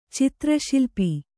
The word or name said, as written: ಚಿತ್ರಶಿಲ್ಪಿ